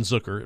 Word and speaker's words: Zucker: You had goals from Jason Zucker. It rhymes with Hooker.